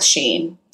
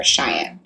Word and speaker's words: Shein: You know, you see that with Shein. – Yeah. – Or Cheyenne.